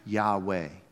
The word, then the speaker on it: Yahweh: We pronounce it Yahweh. No good Jew would ever say this name and profane the name of God. As a matter of fact, by the 9th century A.D., people just forgot how to say it. They took the vowels out. There's no vowels. We don't even know if it's actually pronounced Yahweh.